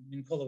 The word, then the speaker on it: Mincolla: Mark with a K and Mincola with two L's.